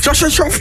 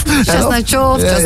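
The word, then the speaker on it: Khachanov: Chasnajov, zeker. – Volgens mij was dat vlekkeloos uitgesproken.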